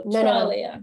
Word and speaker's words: Trelea: They were like, Trelea. And I'm like, no, Trelea.